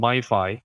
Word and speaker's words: MiFi: I eventually settled on MiFi, which rhymes with Wi-Fi for a reason,